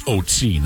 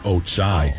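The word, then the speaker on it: Ötzi: He says the mummy is, the pronunciation of its name is Otsi, not Otsai.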